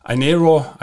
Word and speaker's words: Inero: And in this case, the name of the magic mobile flood barriers is Inero or Inero. Yeah? I-N-E-R-O in English. Inero, I always call it Inero. It's a German pronunciation, but anyway.